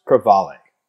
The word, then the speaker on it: crevalle: It's pronounced crevalle, sounding just like its Australian cousin, the trevally.